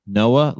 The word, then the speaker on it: Noa: Is it Noah? Am I pronouncing that correctly? Goodman? – You are. Noah, it's like the Ark without the H. – Noah, like the Ark without the H.